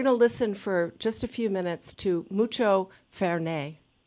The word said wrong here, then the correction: Fernet: Is that how I pronounce it, Dan? – Fernet.